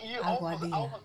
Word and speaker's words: Aguadilla: Looks like you were born in, let me pronounce the word, Aguadilla? Did I pronounce it correctly? – Aguadilla.